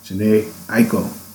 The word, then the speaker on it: Jhené Aiko: Waves by Jhené Aiko.